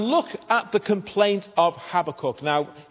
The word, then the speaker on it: Habakkuk: Habakkuk, or you might sometimes hear his name pronounced Habakkuk, but I was brought up with Habakkuk, so that's what I'm sticking with.